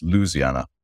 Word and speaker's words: Louisiana: And it concerns a lady, Dr. Rochelle Ford, who's the president of Dillard University, who Benjamin, you tell me, is in Louisiana, which is apparently pronounced Louisiana. And she spoke at the rally a few days ago in Washington, in Israel's defense.